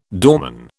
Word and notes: doorman: British English

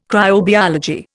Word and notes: cryobiology: Indian

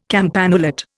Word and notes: campanulate: Indian

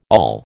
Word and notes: all: American English

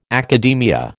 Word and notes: academia: American English

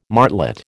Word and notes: martlet: American English

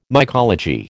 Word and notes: mycology: American English

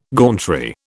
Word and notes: gauntry: British English

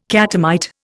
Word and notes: catamite: Indian

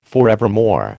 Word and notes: forevermore: American English

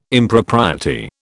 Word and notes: Impropriety: British English

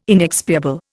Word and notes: inexpiable: Indian

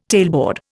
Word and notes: tailboard: Indian

